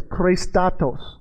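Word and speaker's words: chrēstotēs: So the word goodness is actually pronounced like this, Christatos.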